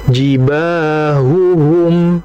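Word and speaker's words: jibahuhum (جباههم): Nah, tadi itu Imam Jazari memberikan suatu amfilah, permisalan, di mana di situ dalam surah At-Taubah, di mana pada kata jibahuhum, ada dua ha, dan dua-duanya harokatnya dhamma. Maka jangan sampai kita mungkin yang pertama makhrajnya enggak jelas sehingga dibacanya apa jibahum, jibahum, jibahum, jadi kayak satu ha. Atau mungkin yang kedua kekeliruannya adalah apa, harokatnya tidak dibaca dengan sempurna, jibahum, jibahum, jangan, tapi yang betul jibahuhum, seperti itu.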